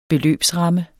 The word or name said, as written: beløbsramme